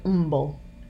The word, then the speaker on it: Humble: Or Stacy just told me yesterday, the street is spelled Humble, and they say Umble.